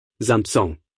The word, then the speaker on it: Samson: Einführung in Samson nun, Shimshan, wie viele von euch sprechen das Wort Samson aus? Mit einem P drin? Samson. Wir sprechen es mit einem P aus. Gibt es ein P in Samson? Was sie tatsächlich tun, ist, dass sie die griechische Aussprache verwenden. Das Griechische hat ein P in sich und ist ins Englische übergegangen.